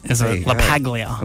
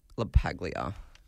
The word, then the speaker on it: LaPaglia: And a big happy birthday to Jonathan LaPaglia. He's 57 years old today. Ex-Survivor host, Hannah. – Gotcha. – Big happy birthday there. – Say his last name again. – Jonathan LaPaglia. LaPaglia.